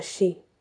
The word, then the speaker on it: Sidhe: So it is spelled S I D H E, Sidhe, but it can be spelled S I, S I D H, or S I T H, but it's pronounced she the Irish word meaning either fairy mound or used an objective to indicate meaning.